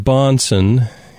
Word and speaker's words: Bahnsen: The word Bonson is spelled B-A-H-N-S-E-N, so it sounds a little different than it's spelled.